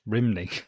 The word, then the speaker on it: Rhymney: So young Aaron attending Rhymney Valley Comprehensive School, who was first introduced to... Sorry? – Rhymney. – Rhymney, I think that's how you say it.